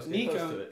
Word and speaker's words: Nikon: Nikon. – Glasses. – Nikon. – It's called Nikon. – Nikon. – Nikon is the greatest. – Nikon. – You gotta pronounce it like Japanese.